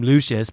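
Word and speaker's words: Lucious: but they call him Lucius, but it's spelled Luscious Lion.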